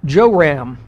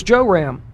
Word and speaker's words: Joram: I believe his name was Joram. That's the pronunciation. It's actually spelled a little differently, but the king of Israel was Joram.